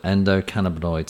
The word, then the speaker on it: endocannabinoid: Endocannabinoid system.